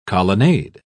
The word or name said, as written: colonnade